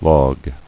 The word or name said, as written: Logged